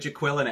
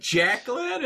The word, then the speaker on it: Jacqueline: Where's Jaqueline at? It's like Jacqueline, and he just gets so angry at her.